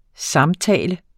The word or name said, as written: samtale